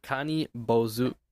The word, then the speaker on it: Kani Bozu: This is Kani Bozu. – Kani Bozu? – Kani Bozu.